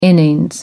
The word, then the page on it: innings: American English